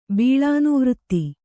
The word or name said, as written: ಬೀಳಾನುವೃತ್ತಿ